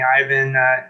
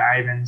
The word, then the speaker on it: Ivan: Some people call me Ivan. People who speak Spanish are also of Mexican-American background, known me for years. They tend to call me Ivan, but in like work, school studying, stuff like that, I go by Ivan.